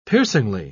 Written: piercingly

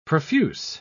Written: profuse

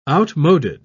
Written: outmoded

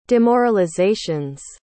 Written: demoralizations

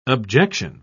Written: objection

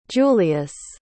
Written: julious

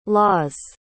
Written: laws

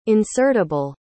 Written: insertable

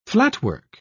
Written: flatwork

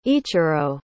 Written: ichirou

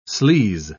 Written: sleaze

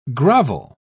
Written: grovel

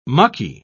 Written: mucky

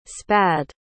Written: spad

